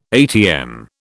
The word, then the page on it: atm: British English